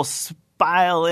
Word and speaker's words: spile: And so you hammer a little spile in there, rhymes with smile, hammer that into the side of the tree, and that's your little drip-drip fountain.